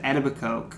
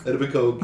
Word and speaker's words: Etobicoke: Oh, and even in that documentary, the Don't Fuck With Cats, they said Etobicoke. – Etobicoke, yeah.